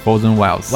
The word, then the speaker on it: Frozen Wilds: 然后是又是一个 DLC，就是这个地平线 Horizon 的 DLC，叫做 Frozen Wilds，啊、哦，我的发音不太准。 – Frozen Wilds， Wilds，对啊。